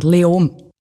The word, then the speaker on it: Leoben: Spricht man es Leoben aus? Wie spricht man das überhaupt aus? – Fast kein Konsonant. Leom.